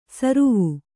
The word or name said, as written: ಸ್ರಾವ